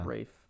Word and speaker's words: Fiennes: Ralph Fiennes. – Pronounced yeah. Rafe, but ugh. Close enough. It's spelled the same.